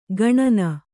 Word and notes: ಗಣನ: gaṇana

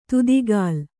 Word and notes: ತುದಿಗಾಲ್: tudigāl

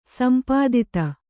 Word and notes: ಸಂಪಾದಿತ: kannada

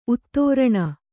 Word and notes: ಉತ್ತರಣೆ: Kannada

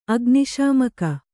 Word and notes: ಅಗ್ನಿಶಾಮಕ: agniśamaka

